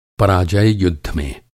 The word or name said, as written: पराजय